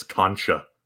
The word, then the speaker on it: concha: But someone in the Discord is complaining about my pronunciation of Konka. Apparently, it's Concha.